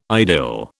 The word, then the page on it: idyll: British English